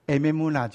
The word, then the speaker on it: emuna: 中间那个是希伯来文，第一个字念作 a，第二个字念作 m，第三个字是 u，可是它的 u 有加 w 的音，叫做 v，所以 emuna 就是神的话语。